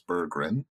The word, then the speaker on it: Bergren: I should mention that his name's going to sound very similar to the way that it was originally pronounced, but there was an extra G and an extra R in there, so it's Bergren.